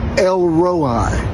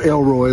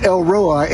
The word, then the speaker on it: El Roi: Sometimes it's spelled Rohi, R-O-H-I. Sometimes it's spelled O-R-I. It's not Elroy like on the Jetsons. His boy Elroy. No, it's El Roi, and it means the God who sees.